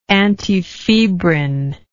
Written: antifebrin

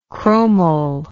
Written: chromyl

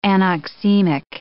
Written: anoxemic

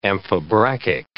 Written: amphibrachic

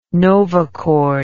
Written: novachord

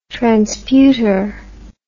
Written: transputer

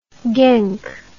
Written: genk

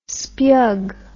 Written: speug

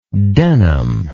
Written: Denham

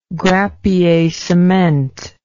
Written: grappiercement

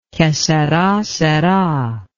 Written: que sera sera

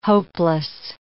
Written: hopeless

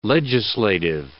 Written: legislative

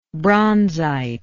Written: bronzite

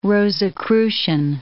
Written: Rosicrucian

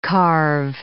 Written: carve

